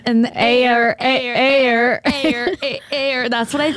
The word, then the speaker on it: Aire: Well, so she posted a cute little photo with his name, which is Air. She did confirm the pronunciation is air, even though it's spelled A-I-R-E.